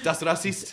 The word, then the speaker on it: Das Racist: Das Racist.